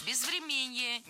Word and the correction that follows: безвременье: неверно, правильно, безвременье. Ударение в этом слове падает на корень врем. Время, безвременье.